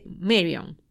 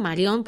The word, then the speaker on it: Marion: Bueno, entonces, después de este escape, a los 6 meses deciden cerrar por fin Alcatraz y abren la prisión de Marion. O sea, yo le digo Marion porque así se dice en español, pero la pronunciación correcta debería ser Marion.